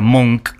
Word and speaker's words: Munch: El libro se llama Encuentro con Munch, que en realidad ella cuenta en el libro que le dijeron con, con dureza que se pronuncia Monk, pero bueno, todos decimos Munch acá.